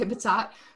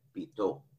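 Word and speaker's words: Bitot: Yeah, I think I've heard batat the most, so I say batat. Is it French? I don't know. What is it? Somebody tell me how to pronounce it. – Bito. – Bito? Yeah, I have no idea.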